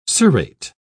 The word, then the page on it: cirrate: British English